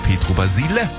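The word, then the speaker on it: Pietro Basile: Mit Aussprechen von Namen ist das schon so schwierig, aber ich habe sie die ganze Zeit immer falsch ausgesprochen. Er hat mich berichtigt beim Schlager-Olymp. Ich heiße Petro Basile.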